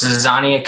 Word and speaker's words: Zizania: Zizania Consulting. Is that also pronounced? – You were close. Zizania Consulting.